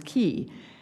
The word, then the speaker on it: quay: Beside the key, I learned the quay, I learned to pronounce as key, as I knew to say Yates, not Yeats.